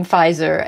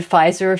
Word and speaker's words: Pfizer: And he has these linguistic ideas about why the word Pfizer feels luxurious, because it has a silent letter in it, because because it's a proper name which people associate with designers.